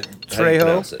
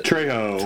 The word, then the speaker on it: Trejo: How do you pronounce his name? Danny Tru, Tru, Trejo. Trejo. Trejo. Right.